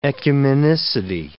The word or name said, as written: ecumenicity